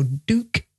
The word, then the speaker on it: duke: So we would say tube, T-U-B-E, as in YouTube. But Americans might say here, tube, duke, D-U-K-E, and duke is another example. Same spelling, just a different pronunciation. So duke and duke. Notice also that here in the British English, we say duke with a J sound, as in the Duke of Edinburgh.